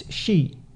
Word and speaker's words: shi: So yeah, the number four, even in Japanese, because of the word, it's shi, ichi ni san shi. That's also from the Chinese okay. – reading of that. Mm-hmm. She is also the verb to die. – Mm, okay. – Yeah. So in Japanese, what they'll do is they'll use the Japanese pronunciation as they go ichi ni san yon. – Okay. – Still four. – Yes. – But they don't say she.